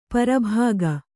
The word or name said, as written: ಪ್ರಭಾಗ